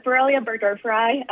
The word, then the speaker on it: Borrelia burgdorferi: Borrelia burgdorferi, if you want to get fancy with Latin.